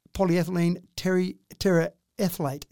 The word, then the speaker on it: polyethylene terephthalate: They're using PET, polyethylene terephthalate. Is that how I pronounce it? – Yeah, that sounds good enough to me.